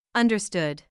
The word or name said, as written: understood